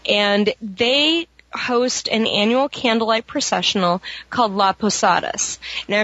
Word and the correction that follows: Las Posadas: It's Las Posadas. – Oh, I'm sorry. – Las... – I forgot the S.